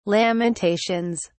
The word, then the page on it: Lamentations: British English